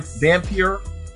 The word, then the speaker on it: Vampyr: Uh, they've also published a game called uh, Vam- Vamp- Vampyr. That's how you pronounce it.